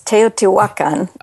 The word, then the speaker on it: Teotihuacan: Teotihuacan. – Okay, it's Teotihuacan.